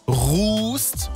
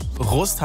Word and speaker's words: Rust: Vorhin haben wir schon gelernt, es heißt Rust und nicht Rust, haben wir geklärt, weil es anscheinend immer noch nicht ganz klar ist. – Rust heißt es jetzt, ja? – Rust oder Rust? – Rust.